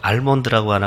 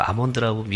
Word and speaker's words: almond: 저는 이 아몬드라는 영어 단어를 볼 때마다 좀, 이게 좀, 불만이 많은데, ALMOND, L자가 들어와 있는데 왜 L 발음을 안 하고 알몬드라고 안 하고 아몬드라고 미국 사람들이 발음하는지